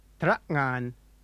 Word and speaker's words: Trak'ngan: Trak'ngan. Trak'ngan. – The sound at the beginning of the second syllable, ngan, is the same as the sound that comes at the end of the word thing or hang.